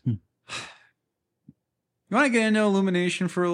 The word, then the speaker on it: Illumination: we were supposed to get the Illumination Entertainment version. – No. You said that wrong. – Oh. – Illumination.